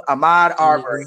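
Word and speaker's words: Ahmaud Arbery: Did I say that right? Ahmad Arbery. Yes, I did.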